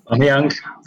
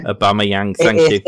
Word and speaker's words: Aubameyang: Angerman from, how do you pronounce it? Angerman? – Aubameyang. – Aubameyang, thank you.